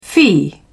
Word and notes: fee: British English

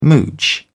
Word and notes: mooch: British English

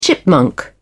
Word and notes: chipmunk: British English

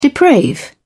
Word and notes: deprave: British English

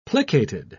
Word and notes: plicated: British English

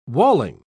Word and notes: walling: British English

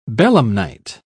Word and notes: belemnite: British English

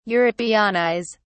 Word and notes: europeanize: British English

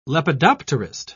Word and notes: lepidopterist: British English

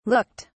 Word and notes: looked: British English

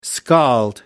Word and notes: scald: American English

